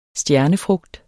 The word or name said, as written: stjernefrugt